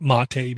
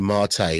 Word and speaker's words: MATE: But if you want to Ubuntu Mate, I'm not calling it Mate because it's spelled M-A-T-E, so it's Ubuntu Mate.